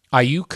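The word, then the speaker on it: Aiyuk: Check out so much during the break, Google Ayuk. – Ayuk? Yes. I don't even know how to spell that. – I think it's A-I-Y-U-K, I think.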